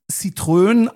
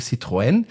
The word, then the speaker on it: Citroën: Ich kenne nur Citroën, aber es wird von den Deutschen gerne als Citroën ausgesprochen, aber es ist natürlich Citroën.